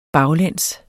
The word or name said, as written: baglæns